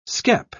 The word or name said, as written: skep